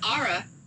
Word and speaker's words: Ara: It's Ara. – Finally. Okay. A-R-A. Is that how you spell it? – Nope. It's just A-R-A.